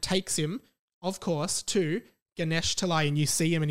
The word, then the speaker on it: Ganesh Talai: But when he was five, he was pronouncing it as Ganeshtali. So he was always looking for Ganeshtali, but it's actually called Ganesh Talai.